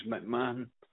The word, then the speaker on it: McMahon: My name is McMahon.